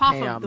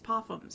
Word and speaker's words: Popham: I thought it was like Popham or something. – We all thought that. – God. – We all thought that. – The British pronunciation is Popham. No, it's Popham. The way you say it is Popham, so that's how it's said.